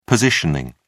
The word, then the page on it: positioning: British English